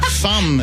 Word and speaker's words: fum: Fum is supposed to rhyme with min, you know, Englishman, fee-fi-fo-fum.